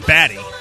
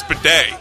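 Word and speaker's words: Badie: Because it reads Batty. – It's not Batty. It's Bidet. – Oh, okay. – Yeah. We all thought, like, because his name's come up in the fantasy community. We all thought it was Batty, but apparently he's told everyone it's pronounced Bidet.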